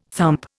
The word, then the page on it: thump: Indian